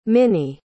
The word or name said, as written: minnie